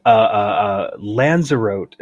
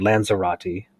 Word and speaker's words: Lanzarote: So completely away from the continent, Lanzarote is least how I pronounce it. You listen to British people say it, and they say every single letter in the word Lanzarote is out in the Canary Islands, down toward Africa there.